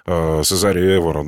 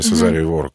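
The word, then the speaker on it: Cesária Évora: Ну, возьмем Сезарию Эвору, да, или Сезарию Эвору, как по-разному ставят ударение.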